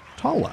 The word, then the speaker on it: Taula: T-A-U-L-A. Tala. – Oh, okay. All right. Tala. Kind of like Paula with a T.